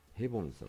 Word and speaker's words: ヘボン: でこのねヘボンさんの名前、ヘボンさん、あの朝ドラのバケバケはヘボンさんでしたけどもね、実はヘボンっていうのは正しい発音ではないんです。なんとヘップバーンなんです、本名は。あのオードリーヘップバーンのヘップバーンなんですよ。そうなんです。ただ日本人には、まあ、当時の日本人には発音しにくいだろうということで、彼自ら、日本における名義としてより分かりやすくヘップバーンではなくてヘボンというふうに自ら名乗ったと。